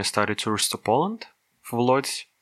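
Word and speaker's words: Łódź: По-польски этот город произносится Удж.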